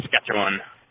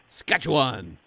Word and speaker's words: Saskatchewan: I can't do it how he did it, but I don't think we pronounce it quite like he did. We kind of just blur it as Saskatchewan. – Saskatchewan.